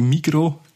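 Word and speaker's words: Migros: Und die Migro, da wird das S nicht ausgesprochen.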